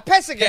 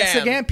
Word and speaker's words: Pesegam: Um, I, I can't even really pronounce it the best. It's Pe- Pesegam? Oh yeah, Pesegam. Pesegam, P E S E G A M.